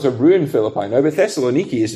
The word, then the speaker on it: Thessaloniki: You'd have to go to the Greek town of Thessaloniki. Is that, am I saying that right? Thessaloniki.